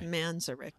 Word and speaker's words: Manzarek: That's how you say his name, by the way. – Manzarek. – Manzarek, yeah. – Okay. – Yeah, or Manzarek, I guess some people, but I was always taught it was Manzarek.